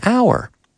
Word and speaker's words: hour: But remember, it's a vowel sound. For example, I was there for an hour, which is an H, but hour. I was there for an hour.